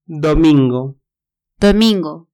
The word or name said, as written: domingo